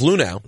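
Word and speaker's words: Luhnow: Well, the GM of the Astros, Jeff Lunau. – Oh, look, you pronounced it correctly.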